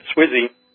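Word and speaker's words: SWSE: Andreas has been working the last couple of years on building a more general semantic web search engine, so we pronounce that Swizzy.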